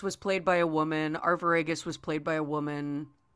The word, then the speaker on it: Arviragus: But the most frequently cited examples is like the prominence of homoeroticism throughout the play with Gerdius and how do you say their names? – Guiderius and Arviragus. – Them. Guiderius and Arviragus.